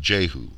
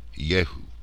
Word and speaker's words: Yehu: as in Yehu, usually pronounced Jehu, when more authentically Yehu. They did not have a J in their language.